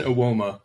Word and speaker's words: Awoma: Like, his name is Tosin Awoma, by the way. That's how you pronounce it.